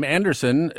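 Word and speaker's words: Andersen: My name, Anderson, is spelled S-E-N. And, of course, every day it's misspelled S-O-N.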